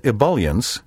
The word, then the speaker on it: ebullience: Be careful not to say ebullience and ebullient, or ebullience and ebullient. The bool and bule sounds are wrong. There are two acceptable pronunciations for the second syllable in these words. Ebullience and ebullient, with the B-U-L pronounced as in the word bulk, or ebullience and ebullient, with the B-U-L pronounced like the word bull.